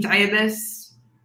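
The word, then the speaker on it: Dabis: On Instagram, Twitter, at Shereen Diabas. – Which is? Last name? How do you spell it? – D-A-B as in boy, I-S.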